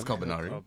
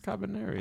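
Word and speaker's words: Kabaneri: Yeah, because it's actually called Cabaneri. Oh, it's not Carbonara. – It's Carbonari. – No, it's just Cabaneri.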